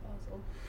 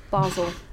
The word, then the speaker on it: Basel: And I think it's pronounced Basel. – Basel. I mean, once again... – But I don't have my phone. – Once again... Yeah, she's right, it is. Switzerland and it's Basel.